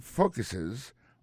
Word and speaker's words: focus: Ça nous carré là, nous carré que dans mot focus là, accent tombé sur premier syllabe là, this program focuses on requesting.